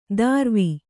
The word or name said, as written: ದಾರ್ವಿ